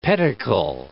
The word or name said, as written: pedicle